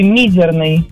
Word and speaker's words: мизерный: Нет, пожалуй, я воздержусь и свое мнение отстаю, что это будет слово «мизерный». Ударение на первый слог.